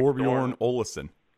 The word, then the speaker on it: Thorbjørn Olesen: Thorbjørn Olesen.